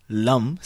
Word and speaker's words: Lum: So we have Lum, spelled L-U-M for the root chakra. Then we've got Vum, V-U-M for the sacral chakra.